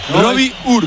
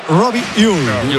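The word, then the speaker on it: Robbie Ure: A ver, el club pasó incluso un audio, lo ha pasado. Y en concreto sería Robbie Uy.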